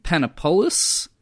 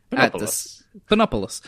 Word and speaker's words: Panopoulos: Because pineapple on pizza, the Hawaiian pizza, created in 1962 by Sam Panopoulos. That's not how you say it. – Panopoulos, at the Satellite Restaurant in Toronto, Canada.